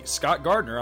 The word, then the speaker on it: Scott Gardner: And hey, you can friend me, Scott Gardner, on Facebook too. My name is spelled S-C-O-T-T-G-A-R-D-N-E-R.